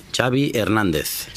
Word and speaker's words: Xavi Hernández: What is the correct pronunciation of your first and last name? – Xavi Hernández. – Xavi. – Okay. Yeah, Xavi like chocolate.